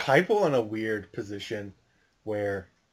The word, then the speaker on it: Heupel: Heupel.